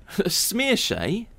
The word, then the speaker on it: Saoirse: They'll say Saoirse, Saoirse, Saoirse, you know, all sorts. My name's Saoirse Ronan. – Saoirse? – Yeah, well, I guess the way, if you look at how it's spelled, if you don't know, S-A-O-I-R-S-E. So Saoirse is an Irish name.